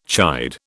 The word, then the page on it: chide: British English